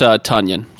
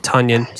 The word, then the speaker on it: Tonyan: I'm going to be investing into a tight end here at this position from Green Bay, and that'd be Robert Tonyan. He is going to be my next pick. – For what it's worth, it's pronounced Tonyan. So. – Tonyan, sorry. – Ton, as in a ton of stuff.